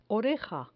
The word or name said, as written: oreja